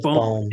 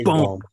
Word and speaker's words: Bohm: you're almost making me say Baum now, because it's Baum. Derek, it's Baum.